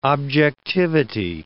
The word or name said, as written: objectivity